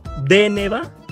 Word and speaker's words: Deneva: que su nombre se pronuncia Deneva con el acento en la primera E.